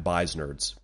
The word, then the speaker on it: Beisnerds: at Beisnerds, B-E-I-S-N-E-R-D-S.